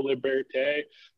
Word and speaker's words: Liberté: I'm trying to actually pronounce it the proper, like, French way of La Liberté. There's, like, you can roll an R into it a little more, but I don't La Liberté.